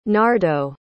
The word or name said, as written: nardo